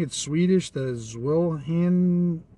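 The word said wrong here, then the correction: Zweihänder: You know what? I'm going to be right back, because I'm going to figure out how to say this. Swayhander. Swayhander. That's what that little YouTube thing kept on saying.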